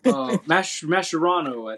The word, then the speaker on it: Mascherano: But, yeah, good call by Mascheroni. I think it's his name, Mascheroni. I'm not good with his name. – I don't want to butcher his name either. Mascherano, I think. There you go.